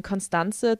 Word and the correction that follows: Constance: Talbot, Constance, ah, Englisch, Constance Talbot.